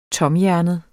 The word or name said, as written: TOMHJERNET